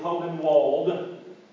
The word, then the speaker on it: Hohenwald: Now, those of you who know it, you know it's spelled H-O-H-E-N-W-A-L-D. It's Hole-in-Walled. If you say it, but the way we Southerners say it, it sounds like what?